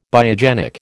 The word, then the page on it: biogenic: American English